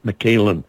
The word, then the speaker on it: Mikalyn: And for those of you who aren't familiar with her name, it's M-I-K-A-L-Y-N. And every time I write it down, I try to move the Y out of place just because of the way her name is pronounced. But it's M-I-K-A-L-Y-N, Michaelin.